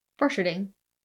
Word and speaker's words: Borcherding: B-O-R-C-H-E-R-D-I-N-G. – Borcherding. – Borcherding? Sure, fine.